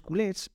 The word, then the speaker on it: culés: culers, con R, culers, es decir, en catalán culo. Y de ahí, bueno, la pronunciación es culés, y entonces eh, empezó a utilizarse ese apodo para los aficionados de Barcelona, los culés.